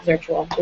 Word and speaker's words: Zirtual: It's just Zirtual, which is spelled like virtual but with a Z.